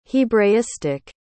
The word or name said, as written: hebraistic